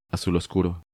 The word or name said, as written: Azul Oscuro